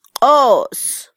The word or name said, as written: qos